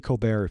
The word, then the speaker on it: Colbert: You only say Colbert if you're from France.